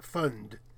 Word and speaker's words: fond: It also came from fond, F-O-N-D. Would that have pronounced fund? That O? – I think it'd fund, like that.